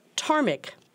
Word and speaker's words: ptarmigan: And the adjective ptarmigan, the first letter of which is a silent P, describes anything that makes you sneeze.